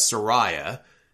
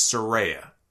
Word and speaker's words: Saraya: She is now going by her first name, which I have been mistakenly pronouncing as Saraya, and she corrected everybody on Twitter and said it's Saraya.